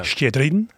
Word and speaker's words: Shchedrin: Shchedrin. Ja. Shchedrin, moet ik misschien zeggen.